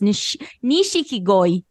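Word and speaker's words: Nishikigoi (錦鯉): Nishikigoi.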